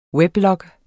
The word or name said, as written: weblog